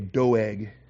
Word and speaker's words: Doeg: Backing up into verse 1, this is when Doug the Edomite came. Good thing Doug's not here today. I'm going to pick on Doug. When Doug the Edomite, okay, Doeg is maybe the better Hebrew pronunciation, but I call him Doug just because I like Doug.